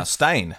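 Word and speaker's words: Steyne: And the North Stein, is that the way you say that word? Stein, sir? – Stein. North Stein.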